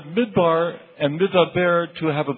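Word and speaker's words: Midaber (מדבר): There are two words in Hebrew that look alike and are spelled alike. – 有两个希伯来字看起来一样，而且发音起来也差不多。 – One is the word Midbar. – 第一个字就是 Midbar，就是旷野的意思。 – And the second is the word Midaber. – 另外一个字就是 Midaber，就是上帝说的意思。 – It's spelled the same way in Hebrew. – 在希伯来字，它其实是它的那个发啊、就是拼法是一样的。 – But they have completely different meanings.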